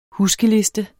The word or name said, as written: huskeliste